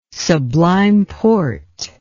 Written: Sublime Porte

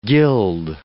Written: guild

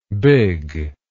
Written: bigg

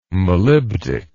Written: molybdic

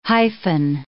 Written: hyphen

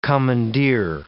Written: commandeer

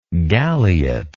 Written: galiot

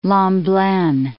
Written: Lomblen

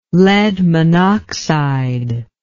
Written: lead monoxide

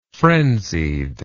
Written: frenziedly

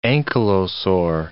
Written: ankylosaur

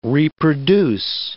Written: reproduce

